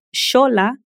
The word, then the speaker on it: Xola: Mas existem ainda hoje algumas palavras que são escritas com X e que mantêm o som de SH. Isso acontece no México e em outras regiões da América em palavras de origem náhuatl, que é uma língua nativa dessa região desde muitos anos. Por exemplo, a palavra xolá se escreve X-O-L-A.